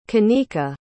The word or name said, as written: canika